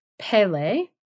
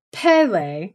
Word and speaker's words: Pele: Because it's Pele, like Pele. A P-E-H sound for like the first one.